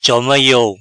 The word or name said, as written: Choma Iou